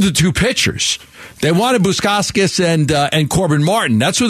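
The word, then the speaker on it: Bukauskas: You can't even say his name right, for goodness sakes. Yeah. – Bukoskis.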